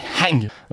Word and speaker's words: Hang: Oui, bah alors ça se dit avec le H aspiré Hang.